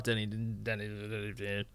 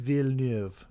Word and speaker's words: Villeneuve: I love Denis Villeneuve.